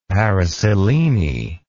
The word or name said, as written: paraselene